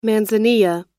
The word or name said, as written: manzanilla